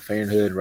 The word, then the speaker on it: Feyenoord: Feyenoord, right? – Yeah.